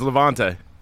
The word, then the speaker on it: Levante: Is it Levante or is it Levitate? – Levitate? No, it's Levante. – It's Levante. – What is it? – It's well, how's it spell it? – L-E-V-A-N-T-E. – Yeah, it's Levante, I think.